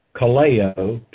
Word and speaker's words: kaleo: Kaleo, K-A-L-E, long O, is the verb, and it means to invite or to name. To name, N-A-M-E.